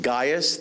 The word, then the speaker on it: Gaius: And again, you pronounce it Gaius. The A-I has a guy sound.